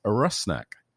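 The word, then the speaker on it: Rusnák: So that you can all be better prepared for my goal-scoring announcements this season, the pronunciation of our new designated player's name is Rusnak.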